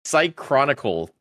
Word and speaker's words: Cychronicle: Uh, Gunvolt Records, uh, Psychron- Uh, how the hell do you pronounce it? Cychronicle. That's how you pronounce it.